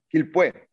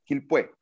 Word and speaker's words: Quilpué: Pero habla precisamente del lugar de ustedes, de donde nacieron, de Arica de Quilpué, ¿cómo se pronuncia? – Quilpué. – Quilpué.